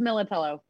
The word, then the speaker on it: Millitello: It's Millitello.